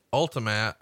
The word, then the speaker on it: Ultimatte: If you'd like to see it today, it's called Ultimat and it's spelled Ultimatte.